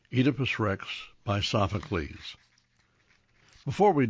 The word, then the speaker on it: Oedipus: The correct pronunciation is a long E, as in Phoenix, Fetus, and Phoebe. The ancient Greek state of Boeotia is pronounced Boeotia. The god of wine is Enus, and Oedipus is pronounced Oedipus.